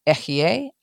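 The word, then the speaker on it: Ehye (אֶהְיֶה): We know this from Exodus 3:14, in which the Almighty explains his name as Ehye Asher Ehye, I am that I am, is how we pronounce, how we and have it interpreted in our Bibles.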